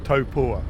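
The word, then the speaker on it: Taupō: we will be racing in Taupo, or the correct pronunciation, Topua. yeah.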